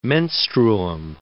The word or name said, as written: menstruum